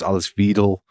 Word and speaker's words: Alice Weidel: And then there was like the fact that he couldn't really pronounce the RFD Chancellor candidate, Alice Weidel's name. It was Alice Weidel.